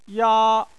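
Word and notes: ya: tone 1

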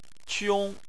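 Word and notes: qiong: tone 1

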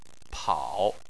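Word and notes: pao3: tone 3

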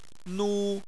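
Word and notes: nu1: tone 1

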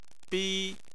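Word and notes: bi: tone 1

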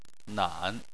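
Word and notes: nan: tone 3

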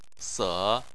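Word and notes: se: tone 3

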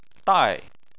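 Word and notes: dai: tone 4

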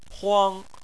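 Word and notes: huang: tone 1

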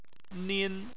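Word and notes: nin: tone 1

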